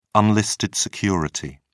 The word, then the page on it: unlisted security: British English